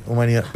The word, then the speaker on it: humanidad: ¿La pronunciaste la D? – Humanidad. ¿Si me pronunciaste cuál? – La D. – No sé, humanidad, dije. – No, te digo humanidad, ¿no? – Sí, humanidad.